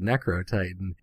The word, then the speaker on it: Necrotitan: But he offhandedly, Bluestreak says the word Necrotitan. And he's like, wait, do you mean Necrotitan? He's like, no, it's Necrotitan. Like, Because it is Necro-Titan, because that's how you say it in an American accent.